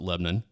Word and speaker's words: Lebanon: Like, we've got a, yes, it is spelled Lebanon. – Yeah. – But everyone around here calls it Lebanon.